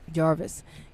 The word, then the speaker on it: Jarvis: her name was Anna Jarvis. Did I say that right? – Jarvis.